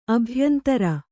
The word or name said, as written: ಅಭ್ಯಂತರ